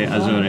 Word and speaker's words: Azure: Is it Azure? And you're like, please don't say Azure, it's Microsoft Azure.